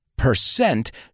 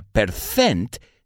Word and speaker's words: percent: Por cierto, percent no se pronuncia percent, sino percent.